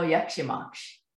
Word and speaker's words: jak się masz: Let's see. I know "dzień dobry," and I know "jak się masz." Am I saying it correctly? – Yes. – That's correct.